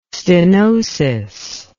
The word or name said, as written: stenosis